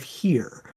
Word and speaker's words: Hwr: A group of scared bucks came running out of the woods with their antlers ablaze and set fire to the village of Here. And that is Welsh, so it's spelled, and because Welsh is a nonsense language, it's spelled H-W-R, which is crazy.